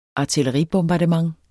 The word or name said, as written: artilleribombardement